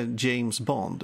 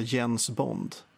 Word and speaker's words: James Bond: Så han kunde inte säga James Bond, utan alltså Jens Bond.